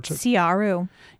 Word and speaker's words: Siaru: Siaru. – Yeah, which Patrick Rothfuss has said the Siaru they're black people.